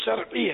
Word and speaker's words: شرعی: ہمارے شرعی تلفظ شرعی ہے, شرعی یہ اہل علم بول رہے ہوتے, شرعی. را پر زبر نہیں ہے, را ساکن ہے, اس پہ جزم ہے.